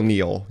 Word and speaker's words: Neal: So I have to say I'm the real Neil, you know, N-E-A-L, not N-E-I-L.